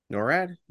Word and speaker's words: NORAD: NORAD.